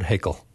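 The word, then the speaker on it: Haykel: Professor Haeckel, is that how you pronounce it? – Yes, Bernard Haeckel.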